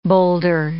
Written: boulder

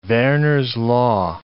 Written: Verner's law

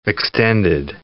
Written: extended

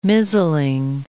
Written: mizzling

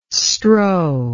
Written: strow

